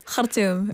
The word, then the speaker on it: Khartoum: Say it again in the correct way. – Khartoum. – That's a brilliant pronunciation.